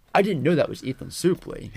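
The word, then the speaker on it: Ethan Suplee: Yeah, Ethan Supley.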